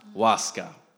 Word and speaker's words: Láska: Láska.